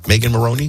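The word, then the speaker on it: Megan Moroney: Megan Maroney, did I say it right? – Yeah.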